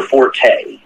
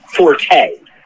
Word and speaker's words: Forte: Apparently, Forte, for the people that think it's a correct pronunciation, is only for the Italian musical direction term for loud and strong, where Fort, the correct pronunciation of the spelling, is strength.